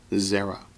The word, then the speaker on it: zera: And the accent's on the first syllable, Zerah.